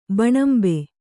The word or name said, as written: ಭೂನಿಂಬೆ